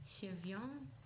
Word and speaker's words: Giveon: Je speelt het als Chevion.